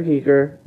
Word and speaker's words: Giger: So, should say, H.R. Giger, that is how it's pronounced.